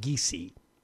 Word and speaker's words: Giese: Her name is pronounced Giese, Gianna Giese.